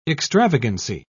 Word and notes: extravagancy: British English

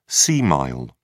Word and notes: sea mile: British English

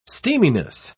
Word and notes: steaminess: British English

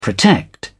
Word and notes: protect: British English

